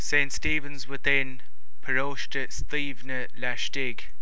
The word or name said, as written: St. Stephen's Without